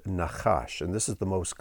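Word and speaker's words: nachash (נחש): It's the word nachash, nachash. It's spelled nun, chet, shin.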